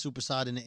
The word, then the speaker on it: soppressata: By the way, how do you pronounce soppressata in American? – Soppressata.